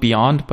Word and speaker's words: Beond: I think you're supposed to call it Beyond, but it's spelled like B-E-O-N-D, that are going to offer Apple Vision Pro for their passengers, like as in-flight entertainment.